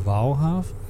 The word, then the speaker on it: Waalhaven: en dan varen we nu volgens mij richting, langzaamaan richting de Waalhaven zeg ik dat goed? – Ja, dat is hier net pal naast.